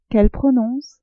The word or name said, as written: qu'elle prononce